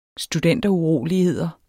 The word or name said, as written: studenteruroligheder